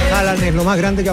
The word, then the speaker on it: Haaland: Me dijo, es Haaland. Como una doble Haaland.